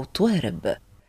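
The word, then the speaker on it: taarab: نعم، طرب أو طوارب، هكذا يتلفظ بالكلمة في اللغة السواحلية وتعني اسم الموسيقى الشائعة في تلك البلدان الإفريقية العريقة.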